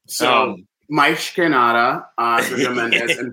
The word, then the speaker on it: mais: No, because Portuguese, mas, M-A-S is Spanish, mas. Portuguese, mais, is how you say mais, is how it's pronounced, mais que nada.